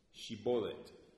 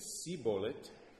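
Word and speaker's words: Shibolet (שיבולת): If he said no, then they would say to him, "Shibolet." And if he said "Sibolet" and could not pronounce the word correctly, then they executed him right there at the river.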